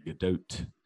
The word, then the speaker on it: Gadot: See, I always said Gadot, but the pronunciation, yeah, she says it's a soft T, Gadot.